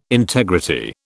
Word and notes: integrity: British English

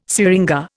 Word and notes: syringa: Indian

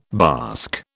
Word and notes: bask: British English

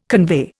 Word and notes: convey: Indian

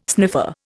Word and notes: sniffer: Indian

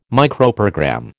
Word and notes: Microprogram: American English

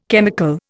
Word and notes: chemical: Indian

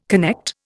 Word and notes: connect: Indian

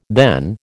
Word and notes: then: American English

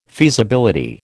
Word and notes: feasibility: American English

